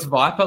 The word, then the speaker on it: Viper: So it's Viper, like the snake, like V-I-P-E-R.